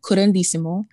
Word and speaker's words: curanderismo: Yeah, so it's called curanderismo, mm-hmm.